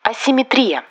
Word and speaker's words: асимметрия: Но пока правильным вариантом является только с ударением на «и» – асимметрия. Для запоминания обратимся к рифме. Экономическая асимметрия. Ест центр, работает периферия.